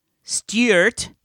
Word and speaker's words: Stuart: I'm Stuart. – Stuart. – From the UK here. Pronounced STYU hyphen URT rather than STOO hyphen URT.